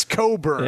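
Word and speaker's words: Cockburn: Stop. You're not pronouncing his name properly. – I don't know. I'm going to have to go look. – It's Coburn. – Yeah. – What did you think it was, Berto? – Yeah. – Not spelled. – The K is silent.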